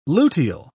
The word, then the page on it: luteal: British English